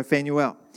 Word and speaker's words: Phanuel: She was the daughter of, I'm going to say this wrong, but Phanuel. Sounds a bit like flannel, doesn't it? But we're going to go Phanuel. From the tribe of Asher.